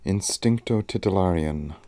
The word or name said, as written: instincto titillarian